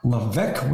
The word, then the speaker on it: Levesque: Sierra, Sierra Levesque with a silent S.